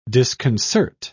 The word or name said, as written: disconcert